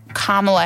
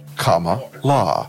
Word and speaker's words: Kamala: Including a tutorial on how to pronounce Kamala Harris's name correctly. – Kamala.